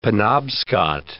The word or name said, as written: Penobscot